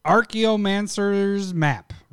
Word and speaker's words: Archaeomancer's map: Archaeomancer's map, right? Did I say it right? Sounds right to me.